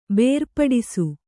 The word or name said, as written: ಬೇರ್ಪಡಿಸು